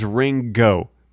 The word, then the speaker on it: Ringo: I guess it is Ringo with a hard G.